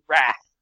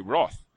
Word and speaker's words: wrath: So, how do you pronounce the word WRATH? – Oh, dear. I pronounce it RATH. – You do? – I know that's the American way, but I do pronounce it RATH. – Oh, you've completely nullified my argument there. I was going to say, I always say Roth.